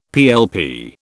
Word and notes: PLP: British English

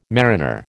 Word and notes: mariner: American English

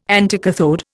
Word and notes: anticathode: Indian

